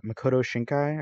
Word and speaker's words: Makoto Shinkai: And this is Your Name, which is directed by Makoto Shinkai.